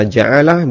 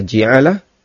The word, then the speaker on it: جعالة: Al-ja'alah bisa dibaca ja'alah, bisa dibaca ji'alah, bisa juga dibaca ju'alah. Dan ada tiga cara baca seperti ini, sehingga baik dibaca ja, ji, ju, semuanya benar ya.